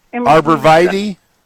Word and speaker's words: arborvitae: They're called the evergreen shrubs, arbortive. I'm saying that wrong. – Arborvitae? – Arborvitae. – Yeah. Arborvitae, yeah.